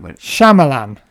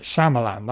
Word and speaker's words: Shyamalan: Shyamalan. That's how you say it. Shyamalan. That's how you apparently say it.